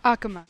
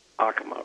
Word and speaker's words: Acoma: So, for example, in the Acoma Pueblo, south of Albuquerque, or Acoma, I may be mispronouncing it. – Acoma. – Acoma, right.